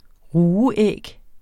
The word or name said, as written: rugeæg